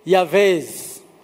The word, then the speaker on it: Jabez: The Jews say Yavez or Yahweh. That's how they say it. The J is Yah. The B is Va. So they say Yahweh, Jabez.